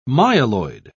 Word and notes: myeloid: British English

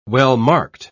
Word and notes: well-marked: British English

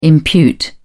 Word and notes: impute: British English

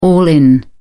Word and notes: all in: British English

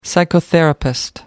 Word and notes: psychotherapist: American English

